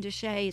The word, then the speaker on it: de Chelly: It's spelled de Chelly, C-H-E-L-L-E-Y, Canyon de Chelly, it's pronounced.